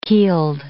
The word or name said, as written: keeled